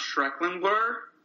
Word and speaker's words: Schreckinger: But anyway, Louis here today as well as Ben Schreckinger. How are we doing, Ben? – Ron, it's actually pronounced Schrecklinger.